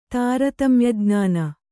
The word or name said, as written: ತಾರತಮ್ಯಜ್ಞಾನ